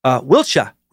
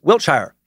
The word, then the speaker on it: Wiltshire: Anything ending in Shire should be Shah, not Shire. We are not hobbits. I love the hobbits reference. Wiltshire. With 2 syllables, not 3 syllables. And it's not Wiltshire. Thank you, John.